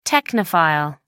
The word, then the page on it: technophile: American English